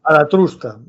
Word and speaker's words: Zaratustra: Zaratustra. No sé si lo he dicho bien. – Zaratustra. – Zaratustra.